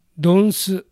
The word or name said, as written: Donsu